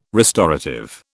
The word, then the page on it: restorative: British English